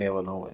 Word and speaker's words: Illinois: My fifth grade teacher told me there is no noise. In Illinois.